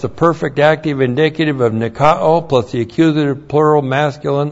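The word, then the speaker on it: Nikao (νικάω): Nikao. N-I-K-A long O. Nikao.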